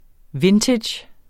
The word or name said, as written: vintage